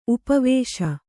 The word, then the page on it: ಉಪವಾಸ: upa vāsa